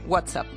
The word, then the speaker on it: WhatsApp: WhatsApp.